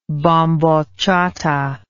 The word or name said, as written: bambocciata